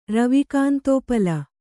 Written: ರವಿಕಾಂತೋಪಲ

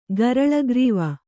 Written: ಗರಲಗ್ರೀವ

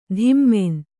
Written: ಧಿಮ್ಮೆನ್

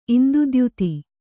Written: ಇಂದುದ್ಯುತಿ

